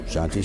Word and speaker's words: Shanti: How do you say Shanti, Shanti, Shanti? – Yeah. Shanti, Shanti, Shanti. Just like they do in Israel now.